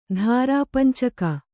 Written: ಧಾರಾಪಂಚಕ

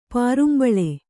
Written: ಪಾರುಂಬಳೆ

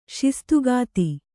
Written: ಶಿಸ್ತುಗಾತಿ

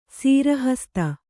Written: ಸಿರಿಹಸ್ತ

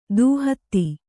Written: ದೂಹತ್ತಿ

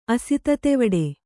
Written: ಅಸಿತತೆವಡೆ

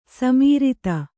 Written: ಸಮರ್ಥ

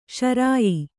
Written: ಶ್ರಾಯ

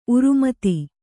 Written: ಉರುಮತಿ

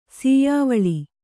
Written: ಸೀಯಾವಳಿ

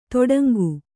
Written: ತೊಡಂಗು

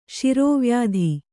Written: ಶಿರೋವ್ಯಾಧಿ

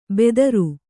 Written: ಬೆದರು

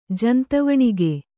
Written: ಜಂತವಣಿಗೆ

